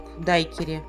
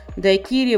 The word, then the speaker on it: дайкири: К слову, на Кубе находится и знаменитый бар-ресторан «Флоридита», э, куда любил захаживать Хемингуэй, чтобы выпить декири Э, кстати, в оригинале ударение в слово «дайкири» на последний слог. Э, в русский язык слово пришло из испанского э, или английского, а там ударение ставится на первый слог «дайкири».